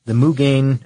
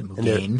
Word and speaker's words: Mugen: It was the Mugen, and I'm saying that right. I know I looked it up. the 2008 Honda Civic Si sedan Mugen edition, and it's M-U-G-E-N. That's how you say it. Mugen.